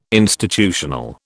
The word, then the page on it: institutional: British English